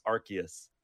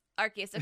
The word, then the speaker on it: Arceus: And I'm like, okay, so that like affirms that that is the correct pronunciation. So it's Arceus. – Arceus.